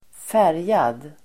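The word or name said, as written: färgad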